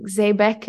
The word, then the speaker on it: Zeybek: Can you tell me how that experience came about and why you were interested in specifically, I think you were learning Zeybek and Denizli, which you can also correct my pronunciation. – No, you're pretty good.